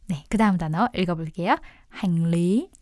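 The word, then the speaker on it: hành lý: 네, 이건 한자어 기원이에요. 중국어로 짐을 싱리라고 하거든요. 그래서 그거랑 발음 틀린 거 아니야? 네, 중국어 끊은 지가 오래돼가지고 네, 발음이 비슷해요. 싱리 이게 중국어고요. 베트남어로는 행리. – 네, 행리. – 라고 합니다.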